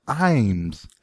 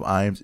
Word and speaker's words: Iams: Someone said here it's pronounced Iams. This dog food brand Iams, founded by Paul F. Iams, is pronounced I-MZ, Iams.